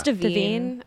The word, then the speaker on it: DeVine: It's Devine. Devine. – Okay. – Because it's pronounced the same way as Levine, like Adam Levine.